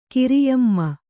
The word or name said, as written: ಕಿರಿಯಮ್ಮ